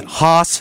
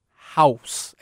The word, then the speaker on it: Haus: Is it Haas House? – House. H-A-U-S.